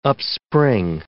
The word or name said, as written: upspring